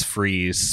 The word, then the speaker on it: Fries: Yes, but later his, like, real, like, Christian name is Freeze, spelled F-R-I-E-S. That's in the animated series and the Night of Owls. He's like Dr. Freeze. – Fries. – Yeah. Large fries. – It's fries. But it's pronounced Freeze, yeah.